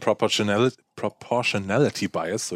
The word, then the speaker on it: Proportionality Bias: Wenn wir schon bei Ereignissen sind, dann sind wir auch eigentlich direkt in der Psychologie, weil es gibt sowas wie den Proportionality Bias. So, jetzt habe ich das Wort richtig ausgesprochen. Der Proportionality Bias sagt praktisch, ein großes Ereignis braucht auch eine große Erklärung.